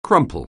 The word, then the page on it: crumple: anglais